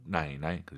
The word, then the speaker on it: 奶奶: 그럼 할머니를 떠올리세요. 할머니. – 奶奶. – 발음 똑같죠? 성조가 晚上, 奶奶. 그죠?